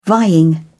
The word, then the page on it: vying: British English